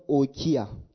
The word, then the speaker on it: oikia: The word house there is the word oikia. I'll spell it for you. O-I-K-I-A. O-I-K-I-A.